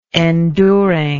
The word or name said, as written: enduring